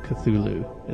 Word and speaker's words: Cthulhu: Well, it's between a ch and a th, so Cthulhu is the correct pronunciation.